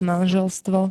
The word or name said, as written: manželstvo